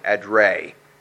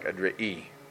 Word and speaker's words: Edrei: and this word is hard to pronounce from the original, but we would pronounce it if you look just like it, like Edrei. Okay, but it would be in the Hebrew, I think it's more like Adri'i.